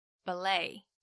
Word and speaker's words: ballet: 那舞蹈世界那么多元，当然也有不少其他风格的舞咯，像天鹅湖啦、胡桃钳这种搭配古典音乐的芭蕾舞，英文说法是 ballet， ballet。只是要小心哦，虽然它的拼法是 ballet，但因为它其实是法文，所以字尾的 t 不发音，在念的时候念法上要多注意。